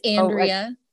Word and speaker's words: Andrea: It's Andrea, but it sounds beautiful.